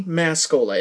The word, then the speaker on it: Mascola: And again, for new listeners, you can find books by searching my name, Andy Mascola. And my last name is spelled M-A-S as in Sam, C-O-L-A as in Coca-Cola.